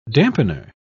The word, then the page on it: dampener: British English